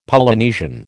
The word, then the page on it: Polynesian: American English